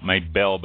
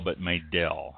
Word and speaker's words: Maydelle: And Maydale is spelled, spelled Maydale, M-A-Y-D-E-L-L-E. – Not Maybell, but Maydale.